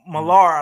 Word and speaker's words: Millar: Mark Milner, or whatever. I'm saying it wrong. I might be saying it wrong. – Mark Millar, I think is his name.